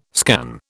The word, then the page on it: scan: British English